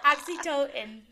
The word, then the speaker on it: oxytocin: It's like Oxycontin and... – No, not Oxycontin. No. That's a drug. Oxytocin, the hug hormone. – Oxytocin, yes. My bad.